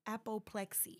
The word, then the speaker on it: apoplexy: I looked that up um, apoplexy. Yep, I said that right.